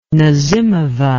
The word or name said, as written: nazimova